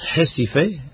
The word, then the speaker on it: Recife: Ro, oni prononcas ĝin ho. Do Recife, oni prononcas Recife, kaj la alia loko estas Rio Clara.